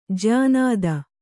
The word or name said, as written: ಜ್ಯಾನಾದ